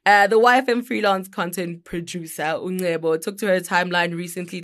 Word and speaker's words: Ngcebo: Okay, so it's NGCEBO. How do I pronounce that? – Nebo. – Yeah. Okay, I'm correct.